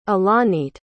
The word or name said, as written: ilanit